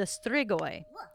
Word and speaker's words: strigoi: In old Romanian folklore, it was believed these babies become the strigoi after their death.